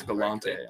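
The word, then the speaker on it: Galante: So these are the things that, you know, Forrest Gallant or Gallant, I don't really know how to pronounce his last name. – It's Gallante, honestly.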